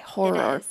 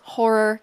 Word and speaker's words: horror: That is a hard word to say. Horror. – It is. – This is Carly. Her podcast is called Final Girls Horror Cast. I said that right? – Nailed it. – Horror. It's like rural juror. Rural horror. Gosh.